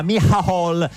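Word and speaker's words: Micheál: No, perché essendo irlandese uno pensa che si pronunci all'inglese, si chiama invece, c'è scritto tipo Michael l'irlandese. – Martin, invece si chiama Michal.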